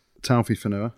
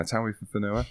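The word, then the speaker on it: Taofifénua: They'd picked two massive second rows in Talofua, how do you say it? – Taufi Fanua. – Taufi Fanua. There we go. Said it second time.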